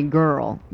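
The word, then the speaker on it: Görl: Now, it has two dots over the O, and I think you pronounce it really girl, but you're going to have to look that up in your own Webster to get the correct one on that.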